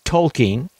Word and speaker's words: Tolkien: Tolkien.